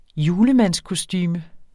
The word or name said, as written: julemandskostume